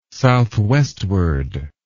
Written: southwestward

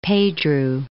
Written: Pedro

